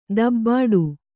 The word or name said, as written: ದಬ್ಬಾಡು